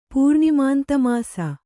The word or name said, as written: ಪೂರ್ಣಿಮಾಂತಮಾಸ